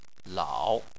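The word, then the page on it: lao: tone 3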